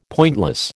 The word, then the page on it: pointless: American English